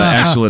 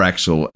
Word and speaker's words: Axle: It's spelled as in the car Axel, A-X-L-E, okay. As opposed to the German spelling, which will be E-L.